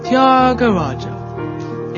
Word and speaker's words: Thyagaraja: It's pronounced Thyagaraja.